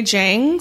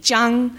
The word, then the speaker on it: Jiang: If I'm speaking to an American, which mostly I am, I'll say Jang simply because the way I would pronounce it in Mandarin, and my Mandarin's not the best either would be Jiang, uh-huh.